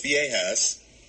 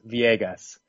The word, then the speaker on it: Villegas: I believe every time I've heard Marcos say his last name, he has used a hard G with that last syllable. Marcos Villegas.